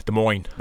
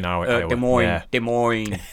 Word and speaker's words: Des Moines: I don't know how you pronounce it really. It looks like Des Moines. – Yeah, Des Moines. – But I'm pretty Des Moines.